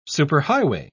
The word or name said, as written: superhighway